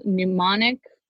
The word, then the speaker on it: mnemonic: So then we have the mild, it's called a mild mnemonic induction of lucid dreams. Mnemonic starts with an M. That's why it's mild. It's a silent M.